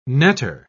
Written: netter

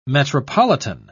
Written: metropolitan

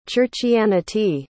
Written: churchianity

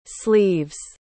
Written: sleeves

